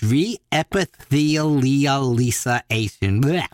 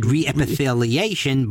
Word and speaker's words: reepithelialization: Which seems like it has an extra two syllables, Leah, Lisa, but it's reepithelialization. – Reepithelialization. – So I would have just said reepitheliation, but nope, it's reepithelialization.